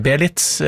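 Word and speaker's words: Beelitz: Vi var i Tyskland, i Belize, Belitz, kanskje riktigere uttale, Belitz in Deutschland.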